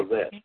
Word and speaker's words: Levesque: Is it Levesque or Levesque? – You got it. – Levesque, okay, all right. I never was able to pronounce that correctly.